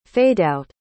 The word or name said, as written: fadeout